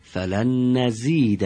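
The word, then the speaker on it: فلن نزيد: آفرین، فلن نزید، این زه معمولی است و انشالله مثل فارسی تلفظ کنید.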